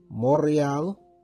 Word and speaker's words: Montreal: He's in Montreal, Quebec, or let me say it properly, Montreal.